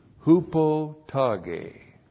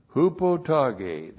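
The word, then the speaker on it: Hupotage (ὑποταγή): On the basis of hupotage. Hupotage. There's the letter H. That's a breathing mouth that's breathing over the word. H. U-P-O-T-A-G, long E. Hupotage.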